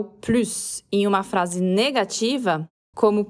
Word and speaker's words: plus: Como é uma negação, a gente não pronuncia o S de plus. Então aqui a pronúncia é com o U biquinho do francês e o S mudo.